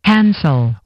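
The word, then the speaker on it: handsel: Hansel.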